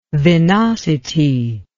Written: venosity

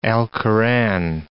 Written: Alcoran

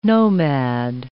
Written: nomad

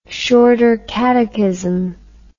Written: shorter catechism

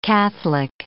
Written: Catholic

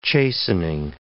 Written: chastening

